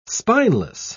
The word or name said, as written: spineless